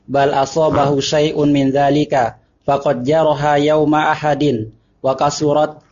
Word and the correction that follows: Uhud: Faqad jaraha yawmu ahadin. – Masa yaum ahad? Ya, hari minggu gitu? – Yaum ahad. – Ah, udah hari minggu gitu, kenaknya ya? – Oh. Faqad jaraha yaum uhudin. – Hari ahad, hari uhud. – Uhud.